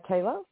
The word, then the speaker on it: Taela: Taylor. – How do you spell it? – T-A-E-L-A. – T-A-E-L-A.